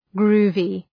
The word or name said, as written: groovy